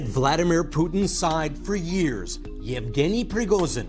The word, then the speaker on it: Yevgeny Prigozhin: It's pronounced Yevgeny Prigozhin. – Yevgeny Prigozhin. – Yeah, that's right.